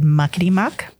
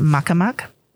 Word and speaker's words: muckety-muck: So if you've ever heard the word muckety-muck, it's also can be pronounced muckamuck.